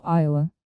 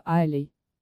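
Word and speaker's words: Islay: Остров Айла. Англичане, в отличие от шотландцев, произносят название этого острова Айлей.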